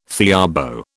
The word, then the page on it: theorbo: British English